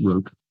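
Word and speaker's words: Rocke: It's spelled Rock with an E on the end, but it's pronounced Roque.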